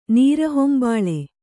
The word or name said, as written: ನೀರಹೊಂಬಾಳೆ